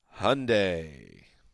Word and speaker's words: Hyundai: Time now for Draft Facts, driven by Hyundai. – Rhymes with Sunday.